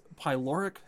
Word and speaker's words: pyloric: And I looked up, it's pyloric sphincter.